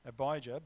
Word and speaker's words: Abijah: And in this time in their life, Jeroboam, he was the king of Israel, and how do you pronounce it? Abijah? A-B-I-J-A-H, Abijah.